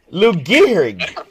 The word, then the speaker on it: Lou Gehrig: Lou Gehrig. yeah, Lou Gehrig. Lou Gehrig.